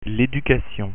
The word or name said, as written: education